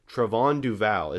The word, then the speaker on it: Trevon Duval: Make sure you say it right, because he made it, went out of his way to correct that it's Trevon Duval is how you say it.